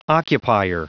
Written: occupier

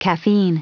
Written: caffeine

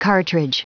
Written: cartridge

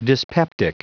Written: dyspeptic

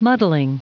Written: muddling

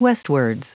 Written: westwards

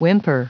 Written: whimper

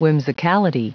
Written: whimsicality

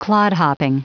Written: clodhopping

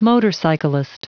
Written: motorcyclist

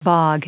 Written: bog

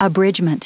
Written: abridgement

